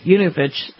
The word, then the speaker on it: Yanukovych: And that's how you say it, by the way. Yanukovych is what I heard today.